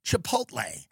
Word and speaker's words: Chipotle: Is it the L-E at the end? Because some people pronounce it the other way. I agree. It's Chipotle.